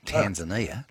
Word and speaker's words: Tanzania: Hey, listen, in Tanzania, that's how they say it over there. We say Tanzania. They say Tanzania.